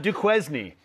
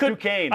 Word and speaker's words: Duquesne: I just wish I could pronounce Duquesne. It is not Duquesne. – It's Duquesne.